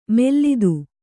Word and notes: ಮೆಲ್ಲಿದು: Kannada

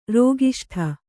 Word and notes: ರೋಗಿಷ್ಠೆ: Kannada

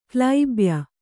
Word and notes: ಕ್ಲೈಬ್ಯ: klaibya

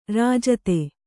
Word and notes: ರಾಜಿತ: rājita

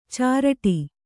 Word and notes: ಚರಟ: caraṭa

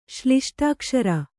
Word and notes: ಶ್ಲಿಷ್ಟಾಕ್ಷರ: kannada

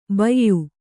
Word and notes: ಭಯ್ಯಾ: Kannada

